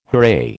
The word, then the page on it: hooray: American English